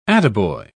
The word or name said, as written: attaboy